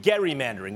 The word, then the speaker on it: gerrymandering: including gerrymandering. Yes, it's called Gary, not Jerry, but it's actually pronounced Gary.